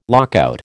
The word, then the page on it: lockout: American English